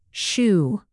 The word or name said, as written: shoo